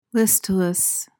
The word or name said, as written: listless